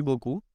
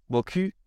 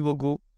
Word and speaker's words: beaucoup: La típica palabra merci beaucoup, eh, que significa muchas gracias. en francés, si pronuncias mal la palabra, puedes decir algo completamente diferente. Y es si dices merci beaucoup Boku significa buen culo.